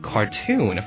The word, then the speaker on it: cartoon: 我们可以用到这个字，叫做 cartoonist， cartoonist， cartoon， C-A-R-T-O-O-N， C-A-R-T-O-O-N， cartoon，发音要记住，不念 cartoon 啊，念作 cartoon，重音是在第二个音节。